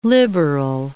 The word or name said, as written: liberal